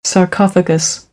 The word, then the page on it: sarcophagus: American English